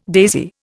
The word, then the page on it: daisy: Indian